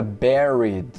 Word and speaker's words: buried: Então a gente tem a, a palavra buried. Se escreve B-U-R-I-E-D, buried, buried, mas a gente pronuncia ba, buried, buried, enterrou as cinzas.